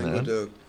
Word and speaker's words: Languedoc: The Venice of Languedoc, I'm sure I'm mispronouncing Languedoc.